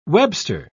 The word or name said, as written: webster